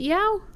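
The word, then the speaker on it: Yeoh: Is it Yao? – Yeah.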